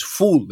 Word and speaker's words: foul: my favorite fava bean dish is ful, it's called, which again, unfortunate translation. And it's the worst thing is it's spelled F-O-U-L. So it looks like foul. It's pronounced ful.